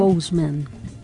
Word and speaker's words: Chadwick Boseman: ¿Batswick? El que se murió. – Chadwick Boseman.